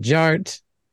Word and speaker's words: Jart: All right, and my name is Jared, aka DJ Art, with two T's for a double dose of that tink tink. The D is silent, so it's just Jart.